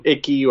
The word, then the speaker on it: Icke: You know, I think we're calling him Icky or some people call him David Icke. It's his last name is spelled I-C-K-E.